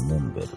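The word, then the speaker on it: mont-bell: じゃあリスナープレゼントできんけジミーちゃん自分で作って多分アウトドアブランドのモンベル。 – あ、この英語の単語は見たことある。これモンベルって読むんだ。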